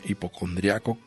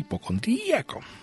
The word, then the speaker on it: hipocondríaco: viene hipocondriaco con acento en la I, hipocondríaco, y viene sin acento. Son aceptables las dos acepciones.